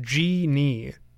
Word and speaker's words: Djinn: So this is a tape by a group that I thought was pronounced Jin. It's spelled D-J-I-N-N. But here it says pronounced Genie.